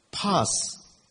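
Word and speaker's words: 파스 (πᾶς): 이 무리라는 단어를 뭘 썼나 이렇게 들여다봤더니 한번 따라서 할까요? 파스. 결릴 때 붙이는 그 파스 발음하고 똑같아요.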